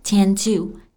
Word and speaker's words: tend to: 我们在读这句话的时候呢，要注意 tend 它的尾音的，因为后面接的是其他的辅音，所以可以适应爆破不发出来，跟后面的 to 连在一起，也就是读作 tend to, tend to.